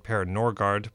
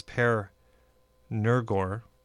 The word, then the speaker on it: Per Nørgaard: So we're in the same time period, although instead of Germany, we have a Danish composer here, whose name I always pronounce as though it's straight, Per Norgard, but that's not correct. It's Per Nørgaard.